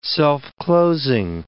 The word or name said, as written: self-closing